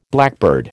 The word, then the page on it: blackbird: American English